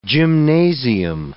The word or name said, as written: gymnasium